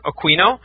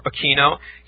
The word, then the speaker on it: Aquino: But at any rate, in terms of Colonel Michael Aquino, and it's pronounced two ways, it's either Aquino or Aquino,